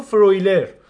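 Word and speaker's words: Freuler: بعد از حضورت که یه مدتی هست میبینمش تو آتالانتا خیلی هم دوست دارم تلفظ چیزی ولی دیدم گزارشگر انگلیسی میگه فرویلر حالا دیگه درست و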